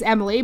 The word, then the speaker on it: Emmalee: So my name is Emily, but it's spelled E-M-M-A-L-E-E. But when I was born, my parents spelled it E-M-I-L-Y, and they did not switch the spell.